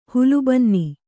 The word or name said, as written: ಹುಲ್ಲುಬನ್ನಿ